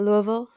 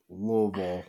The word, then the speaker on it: Louisville: I asked my mom this once, and she was like, you want to say it like you have, like bourbon in your throat. And it's like Louisville. Like, you've got, like, gravel in there. Louisville.